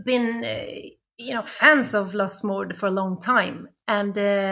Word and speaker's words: Lustmord: Lustmord. – That's perfect. – Okay. – That's how he would pronounce it himself because he's Lustmord.